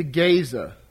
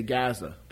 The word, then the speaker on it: Gaza: "Now Samson went to Gaza." That's the more usual pronunciation in the past. Today we say Gaza, the Gaza Strip.